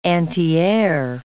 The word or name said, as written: antiair